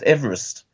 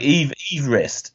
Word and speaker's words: Everest: His name's not even pronounced Everest. – Right. – It was like Everest is how he pronounced his name.